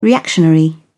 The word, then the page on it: reactionary: British English